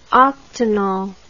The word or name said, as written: octanol